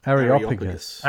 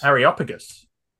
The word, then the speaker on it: Areopagus: They took him and brought him to a meeting of the, oh, how do I say this? Are, Areopagus? – Areopagus. – Areopagus. Excellent.